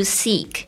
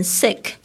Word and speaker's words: seek: What you seek，这个 seek 就是 looking for，就是在找寻什么东西。特别注意一下发音，这个 ee 的声音呢不要念得太短哦，如果你念成 sick 的话就会变成生病， S-I-C-K 那个单词。